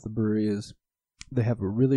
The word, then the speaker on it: Bruery: We keep saying the brewery. It's spelled B-R-U-E-R-Y. – Yeah, that's why it's the brewery.